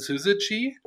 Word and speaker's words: Syzygy: Also geschrieben wird das Unternehmen ja SYZYGY und gesprochen wird das Syzygy, das ist ein Online-Marketing-Spezialist.